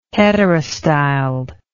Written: heterostyled

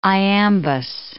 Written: iambus

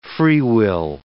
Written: freewill

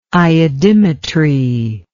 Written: iodimetry

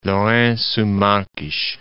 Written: Lourenco Marques

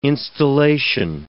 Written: instillation

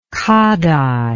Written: Kadai

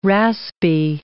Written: raspy

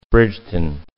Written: Bridgeton

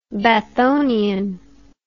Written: Bathonian

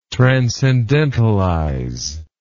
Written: transcendentalize

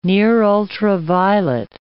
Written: near-ultraviolet

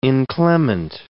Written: inclement

